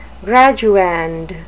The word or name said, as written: graduand